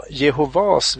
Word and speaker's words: Jehova: En sak som jag reagerade på, det var just det att man sa Jehovas vittnen och inte Jehovas vittnen. Eh, säger ni Jehovas vittnen? Precis, betoningen Eller? ligger på sista A. Jehova.